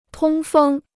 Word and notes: 通风: tōng fēng

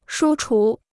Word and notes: 书橱: shū chú

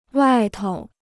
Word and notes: 外头: wài tou

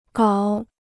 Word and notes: 搞: gǎo

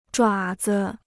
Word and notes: 爪子: zhuǎ zi

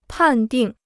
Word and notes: 判定: pàn dìng

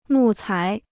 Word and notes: 木材: mù cái

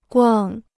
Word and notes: 逛: guàng

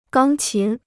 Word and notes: 钢琴: gāng qín

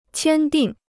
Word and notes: 签订: qiān dìng

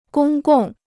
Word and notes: 公共: gōng gòng

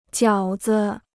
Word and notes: 饺子: jiǎo zi

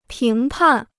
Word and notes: 评判: píng pàn

